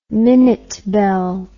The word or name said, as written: minute bell